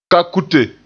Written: kakute

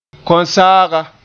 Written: konsaaga

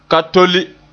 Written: ka tole

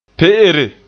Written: pe'ere

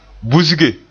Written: busge

